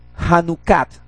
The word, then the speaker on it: Hanukkah: De pasada, esta es la única mención en la Biblia de una fiesta que se llama la fiesta de la dedicación, o en hebreo, Hanukkah. No, está bien pronunciado realmente, la fiesta del Hanukkah.